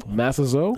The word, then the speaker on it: Masazo: Masazo. – Yep. – Nonaka. – You say Masazo? – Masazo. – Ah.